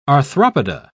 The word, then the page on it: arthropoda: British English